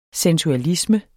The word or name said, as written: sensualisme